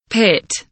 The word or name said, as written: pit